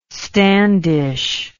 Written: standish

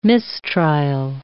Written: mistrial

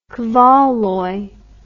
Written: Kvaloy

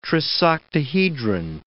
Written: trisoctahedron

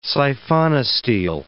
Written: siphonostele

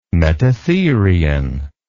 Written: metatherian

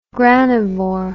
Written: granivore

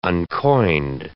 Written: uncoined

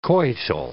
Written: coital

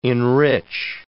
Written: enrich

